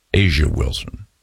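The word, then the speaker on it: A'ja Wilson: And the Fever will be taking on the Las Vegas Aces with Asia Wilson. Asia Wilson. I say it right.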